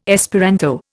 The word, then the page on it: Esperanto: Indian